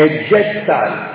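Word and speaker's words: Jetztzeit: La parola, l'esatta pronuncia, mi sono fatto aiutare da Google nel frattempo, è Jetsite.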